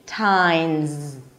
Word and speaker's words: Valentine's: The S is actually pronounced as a Z, tines.